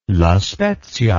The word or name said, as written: La Spezia